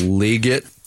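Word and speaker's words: Legette: saying we don't want Xavier Worthy or Xavier Leggett. That was a big surprise to me. – I believe that's pronounced Leggett.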